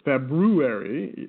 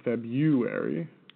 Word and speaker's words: February: It's February, not February. – Right. – It's a lazy pronunciation we have.